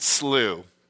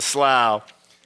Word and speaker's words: slough: Well, it's Apollyon speaking, so he would probably pronounce it slew. It's Apollyon. You can't trust his pronunciations. But if it was a God-fearing, it'd be the slough.